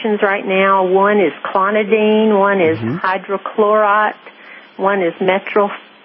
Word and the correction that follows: metoprolol: I can't pronounce it. Metoprolol. – It's called metoprolol.